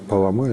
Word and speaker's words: Палама: Ну, ударение можно ставить и так, Палама или Паламы, потому что вот в греческом таким образом, в церковнославянском другим.